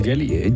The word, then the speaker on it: Geely: Vamos a decir Geely.